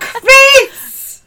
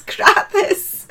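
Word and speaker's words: Crathes: And I kept screaming at the screen, going, it's not Craith's. It's not Craith's. It's Krathis.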